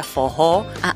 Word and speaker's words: forró: Que bueno, en portugués se pronuncia fojó, la doble R suena como una J, una especie de J para nosotros.